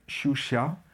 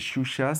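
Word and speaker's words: Sciuscià: Et Shusha, c'est la façon maldite de dire shoeshine, puisque c'est des petits cireurs de chaussures qui arrivent mal à prononcer l'anglais. Donc au lieu de shoeshine, ils disent shusha.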